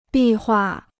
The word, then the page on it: 壁画: bì huà